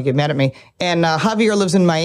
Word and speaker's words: Javier: Javier. Yes. Yeah, the J is silent.